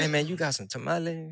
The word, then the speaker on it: tamales: And I got to say, tamales, not tamales. It's tamales.